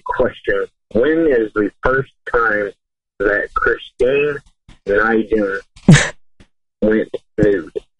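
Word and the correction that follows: Nguyen: All right, I think he pronounced it's Nijin, right? – It's Win. Like yeah. I won the Summer Olympics. Win.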